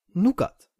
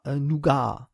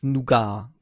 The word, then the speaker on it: Nougat: Vielleicht hat es deswegen nicht geklappt und deswegen heißt es jetzt Nougat, ja, und es heißt Nougat, genau, und nicht etwa Nougat, ja, dass die amerikanischen Social Networks sind voll davon, ja, mit Ausführungen, wie das jetzt richtig ausgesprochen wird, ja, richtig ist, das ist ein französisches Wort, ja, im Französischen wird es Nougat gesprochen. Im Englischen, also Oxford-Englisch, ebenfalls Nougat. Ja, und im Amerikanischen Nougat.